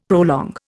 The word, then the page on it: prolong: Indian